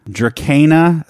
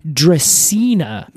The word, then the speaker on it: Dracaena: Now, Alex, I will note, you did do the Greek pronunciation of this word. – The hard C. – The hard C, rather than what a lot of people would say is Dracaena. – Dracaena. – Cinnabari. – Okay. – I think it's fair to say either one is okay.